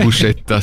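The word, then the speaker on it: Buscetta: Non, Bouchetta, c'est comme ça que ça se prononce.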